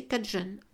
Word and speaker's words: Cajun: Les descendants des Acadiens, prononcés Acadian, sont maintenant connus sous le nom de Cajun, prononcé Cajun.